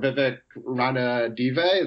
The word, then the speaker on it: Vivek Ranadive: Like, it took his picking up his 17th technical for Vivek Ranadive.